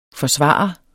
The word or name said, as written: forsvare